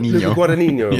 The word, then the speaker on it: Luca Guadagnino: Luca Guadagnino.